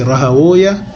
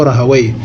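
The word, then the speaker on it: Rahawayh: E o seu nome pronuncia-se Rahawiya ou Rahawai.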